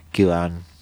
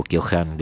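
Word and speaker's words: Keohane: Podemos hablar allí, sí, de interdependencia compleja, Kyohan o Kyohan, depende cómo quieran ustedes pronunciarlo.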